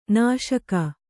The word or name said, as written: ನಾಶಕ